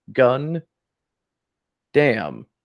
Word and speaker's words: Gundam: So we will make it Gun Dam. And you put it together, it looks like it's pronounced Gundam. So that's how we get the name Gundam.